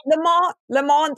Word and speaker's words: Le Mans: All I know is you don't pronounce the S, so I think you're okay there. – Okay, what are you saying? Le Mans? – Le Mans, that's what I was told.